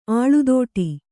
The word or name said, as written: ಅಲೆದಾಟ